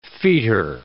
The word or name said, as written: fetor